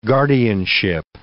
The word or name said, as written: guardianship